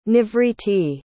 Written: nivritti

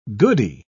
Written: goody

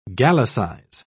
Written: gallicize

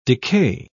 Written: decay

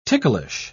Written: ticklish

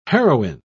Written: heroine

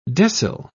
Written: decile

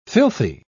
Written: filthy

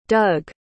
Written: doug

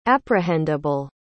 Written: apprehendable

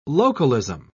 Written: localism

